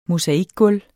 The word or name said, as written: mosaikgulv